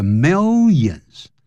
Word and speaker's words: millions: 好，接着往下看， every day，每一天呢， millions of people，千万不要念成 millions，在美式英语叫 millions， mill 再来一个 y， millions， billions，十亿啊， one billion， one million，一百亿。